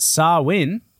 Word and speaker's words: Samhain: So it's a Gaelic word pronounced Samhain. Mm-hmm. And it's spelt Samhain because we know how good the Gaelic are at pronouncing things.